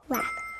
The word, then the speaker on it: wrap: Wrap. – That's right. 포장해요. 포장하다, wrap. 여기 앞에 R 소리 들렸죠?